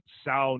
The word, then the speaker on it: Sauk: The Blackhawks, I mean, first off, the logo is historically accurate because they involve the Illinois, I don't know how to say it, that Sau Nation or S-A-U-K Nation in the logo planning, and they just keep a relationship with that community, and they give money to it as well.